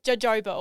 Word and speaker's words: jojoba: It is spelled J O J O B A. So jojoba oil. Yep. but it's pronounced jojoba. It makes no sense.